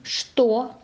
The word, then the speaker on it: что: Скворечник, что, конечно, скучный, чтобы. Посмотрите, можете посмотреть словарики. Но запомните. Скворечник, что, конечно, скучный, чтобы. Мы пишем согласно «ч», а говорим «скворечник». Что, конечно, а произносим «ш».